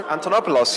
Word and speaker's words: Antonopoulos: Antonopoulos, yes.